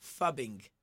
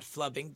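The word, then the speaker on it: phubbing: So the word is fubbing, not flubbing.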